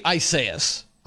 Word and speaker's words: Isaias: It should be Isaias.